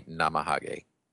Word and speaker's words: Namahage: And I believe the Japanese pronunciation would be Namahage.